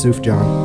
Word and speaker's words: Sufjan: It's pronounced Sufjan. – Can I say Sufjan? – Sufjan.